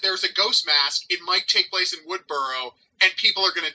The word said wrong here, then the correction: Woodsboro: Okay, first of all, it's pronounced Woodsboro, there's an S.